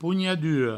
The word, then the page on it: ponhadura: pougnaduro; provençal